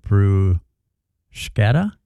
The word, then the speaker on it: bruschetta: Also, bruschetta is pronounced wrong a lot of times. It's a hard K sound, not a sh. So, bruschetta? Is that what you're saying? Bruschetta. Bruschetta.